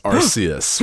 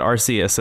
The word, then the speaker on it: Arceus: Pokemon Legends Arceus, right? – Arceus. – Sorry, God. – I think everybody in the world has been pronouncing it Arceus,